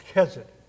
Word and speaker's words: chesed: The Hebrew word translated as kindness here, it's pronounced chesed.